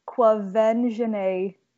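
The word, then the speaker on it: Quvenzhané: I think you're right. It's Kwavenjene.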